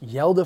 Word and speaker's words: Hjalte: Um, it's actually pronounced Yelda Froholdt. Sorry, my, my spelling is terrible. He spells it H J A L T E.